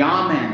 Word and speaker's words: Amen: The Amen, that's the way to pronounce it.